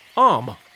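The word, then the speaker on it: homme: So when he pointed to the word man, which he had printed upon a piece of bark, he learned from D'Arnot that it was pronounced om, and in the same way he was taught to pronounce ape singe and tree arbor.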